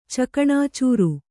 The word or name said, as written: ಚಕಣಾಚೂರು